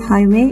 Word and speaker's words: highway: The stress on the word highway is. The next word is highway. Highway. H I G H W A Y. Highway. The stress on the highway is on the first syllable, means on high.